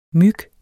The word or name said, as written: myg